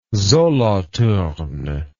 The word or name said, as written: Solothurn